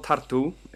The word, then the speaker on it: Tartu: But it's called tartu, T-A-R-T-U, with an accent on the U.